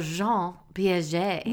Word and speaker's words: Jean Piaget: We talked about Jean Piaget.